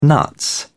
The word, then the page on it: nuts: British English